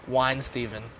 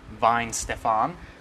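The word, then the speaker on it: Weihenstephaner: Yep, I'm pretty sure it's called Wine Steven. – Mm. I think it's pronounced Vine Stefan.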